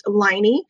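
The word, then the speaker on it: Laine: And so I'm Brazilian, so my name is spelled differently than what it sounds, even though it's pronounced Liney, like the Liney Kugel beer from Wisconsin.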